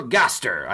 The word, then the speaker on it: Gaster: You did the Jean-Paul, is it Gaster? – Jean-Paul Gaster. I made sure I checked the pronunciation on Google first. Yeah, cool, because Google's never wrong on that.